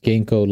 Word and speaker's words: Ginko: It's pronounced Ginko like the herb, G-I-N-K-O, Ginko.